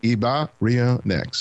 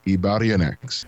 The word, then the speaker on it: Ibarionex: My name is pronounced Ibarionex, so it's Ibarionex.